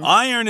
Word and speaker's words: iron: Iron is a tricky word here because it's pronounced iron, not iron. I hear that a lot from people learning English, and it's understandable because of the way it's spelled.